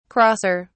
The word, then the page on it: crosser: British English